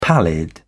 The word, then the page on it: pallid: British English